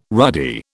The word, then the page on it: ruddy: British English